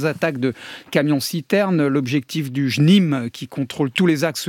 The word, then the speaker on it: JNIM: L'acronyme de ce groupe djihadiste, qui fait régner la terreur au Mali, c'est JNIM, comment on prononce ? – Le JNIM.